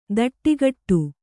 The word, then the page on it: ದಟ್ಟಿಗಟ್ಟು: daṭṭigaṭṭu